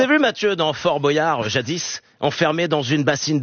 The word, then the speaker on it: Boyard: Non, Boyard. On devrait dire Boyard. – J'avais raison. Boyard comme boyau, voilà.